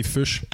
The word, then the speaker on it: Fuchs: Mais non, la vraie prononciation, c'est fuchs. – Okay. – Comme la couleur fuchsia. – Ah, oh, OK, fuchs. – Ouais. – OK. – C'est écrit pareil comme la couleur fuchsia. Puis, okay. Vu que c'est allemand, en fait, les Allemands, ils prononcent Fuchs. Puis ça, c'est comme en anglais, Fox, F-O-X, donc renard. Fait que mon nom de famille, c'est renard en allemand.